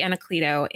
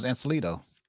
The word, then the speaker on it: Anacleto: I've been saying his name wrong this whole time. I thought it was Ancelito. – Anacleto.